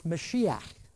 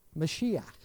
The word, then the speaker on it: Mashiach: It's the word Mashiach. Mashiach. Does that sound familiar with a biblical word that you're familiar with? Messiah, absolutely right. Mashiach is Messiah,